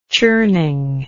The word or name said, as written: churning